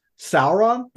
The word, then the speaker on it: Sauron: I think it should be pronounced Sauron.